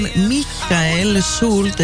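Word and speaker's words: Michael Schulte: Μάλλον Μίχαελ Σούλτ, έτσι για να πούμε και σωστά με προφορά το όνομά του, από τη Γερμανία.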